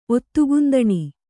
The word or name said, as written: ಒತ್ತುಗುಂದಣಿ